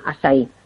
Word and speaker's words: açaí: Si lo queréis buscar escrito, que se escribe A con la cedilla, como si fuera esta portuguesa, y se llama eso Acai orgánico, y se pronuncia açaí.